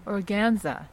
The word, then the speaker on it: organza: Organza. Sheer, ethereal. – Wow. – Yeah. – What's that? – It's a very... You can have a silk organza or like a polyester organza.